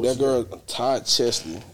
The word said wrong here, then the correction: Chrisley: Say who? – Todd Chrisley. – Todd Chrisley. I said that nigga so crazy. – You said it wrong.